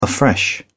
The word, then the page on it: afresh: British English